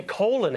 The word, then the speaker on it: Colin: Do they always say Colin? – They're Colin. How did he get that pronunciation? – You tell us about your juice cleanse.